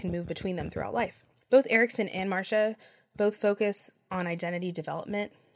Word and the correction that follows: Marcia: I said Marsha's name wrong earlier. It is James Marsha, not Marcia. So Marsha.